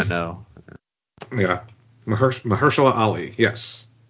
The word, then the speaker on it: Mahershala Ali: Mahershala Ali. – Okay. – Mahershala Ali.